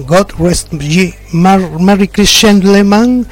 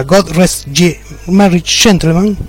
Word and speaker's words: God Rest Ye Merry Gentlemen: God Rest Ye Merry Gentlemen, dije cualquier cosa, sería God Rest Ye Merry Gentlemen, sería la pronunciación correcta, y logran una versión bastante interesante.